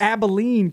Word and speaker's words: Abilene: Did you just say Albine Christian? – I don't know how to pronounce anything, man. You know this. – Abilene Christian. – Abilene, man.